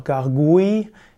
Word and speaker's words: Gargouille: Gargoyle, auch ausgesprochen Gargoyle oder Gargoyle oder auch Gargouille im Französischen, G-A-R-G-O-U-I-L-L-E, sind mystische Wesen, die als Wasserspeier an verschiedenen Gebäuden installiert werden.